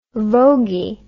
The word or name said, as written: voguey